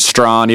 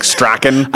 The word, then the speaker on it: Strachan: It, now, it's pronounced Strawn, even though it looks phonetically like Strachan. I, I, can't. I have to call him Strachan.